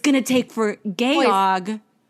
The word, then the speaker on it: Georg: It's the German Georg for George, but with a British accent, which is Georg.